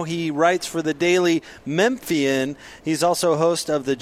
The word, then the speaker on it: Memphian: It's the Daily Memphian.